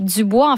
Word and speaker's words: Du Bois: Il s'appelle Web Dubois. En fait, on prononce Du Bois.